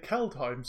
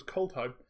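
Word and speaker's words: Kaldheim: And also nice to understand, I say it Keldheim, so I didn't realize it was Coldheim. – I started to try and pronounce it as Keldheim, and then I watched the live stream announcement and everything was Coldheim, and Jimmy Wong said Coldheim, and so after that Jimmy Wong says it, and that's Jimmy Wong of the Command Zone, therefore it's Coldheim.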